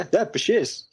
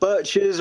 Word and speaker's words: Bichirs: Yeah, Bichirs. Spot on. Not birches or bikers or anything.